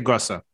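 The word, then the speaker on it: Gösser: Gosser. Yeah. How do I pronounce that? – I would say Gossa, but it's been so long since I've spoken German.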